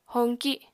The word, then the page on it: hoñki: apatani